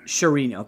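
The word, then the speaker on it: Charania: According to sources, I think it was The Athletic and Shams Sanaria. I probably butchered his name. – Shirinia. – Okay, thank you. – You said it wrong again. Shirinia. There you go.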